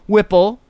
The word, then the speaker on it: Whipple: Whipple, rhymes with nipple, contends it's likely Wilson loaded the gun while Lavely Davidson briefly stepped away to use the potty.